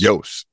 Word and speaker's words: Iooss: Let's move on to spotlight on Walter Hughes. – Yes. – Yost, excuse me, Yost.